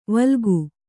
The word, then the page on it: ವಿಲೋಕ: kannada